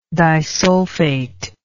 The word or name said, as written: disulfate